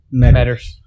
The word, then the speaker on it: Meadors: It's at Mark, how do you pronounce his last name? Meters? – Meters. – Meters, MEADORS underscore on Instagram.